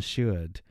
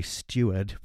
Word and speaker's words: Seward: So there's Quincy Morris, Arthur Homewood, and her other suitor is Dr. John Seward. I always struggle not to say Steward, but it's not, it's Seward.